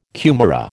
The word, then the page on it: kumara: American English